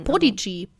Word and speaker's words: Podigee: Wie wird das denn aus ausgesprochen? Weißt du das? – das Podigee, würde ich sagen. Podigee.